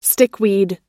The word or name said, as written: stickweed